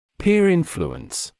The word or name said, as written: peer influence